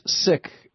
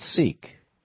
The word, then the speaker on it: Sikh: It's Sikh, not Sikh. – That's right. It's Sikh. The K and the H are kind of aspirated.